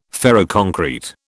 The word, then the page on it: ferroconcrete: British English